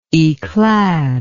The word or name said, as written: yclad